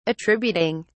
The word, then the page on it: attributing: British English